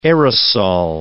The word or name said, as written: aerosol